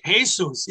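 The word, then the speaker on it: Jesús: How do you say that? – Jesus. – Yeah. See, I always get mixed up with those.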